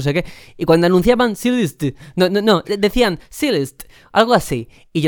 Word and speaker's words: Celeste: De hecho, Celeste es un nombre en inglés muy poco usado y es Celeste. – Celeste sí. No es un color. Pero efectivamente, me costó muchísimo captar ese matiz, esa pronunciación acostumbrado al celeste español.